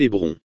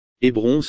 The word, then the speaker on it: Hébron: Lorsque l'épouse bien-aimée d'Abraham, Sarah, décède dans la région d'Hébron, beaucoup d'entre nous dans notre tradition anglaise l'appellent Hébron. Hébron se prononce en fait Hébron.